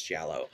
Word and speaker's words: giallo: I believe it's giallo. – Giallo. – Giallo. – Yeah, well, it's basically according to the YouTube pronunciation guide I just researched, it was giallo.